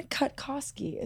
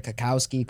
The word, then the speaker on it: Cutkosky: Ethan Cutkosky is here. – Hello, Ethan. correctly. Did I say it right? Oh, you did.